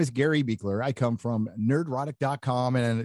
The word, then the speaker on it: Buechler: Hello, Mr. Gary, I'm going to say it wrong, Beckler, yeah? – It's Beekler. You were close.